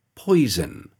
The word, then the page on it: poison: British English